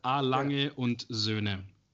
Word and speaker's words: A. Lange und Söhne: So what about A. Langenson? , No, you're getting really close, actually. It's A. Lange und Söhne.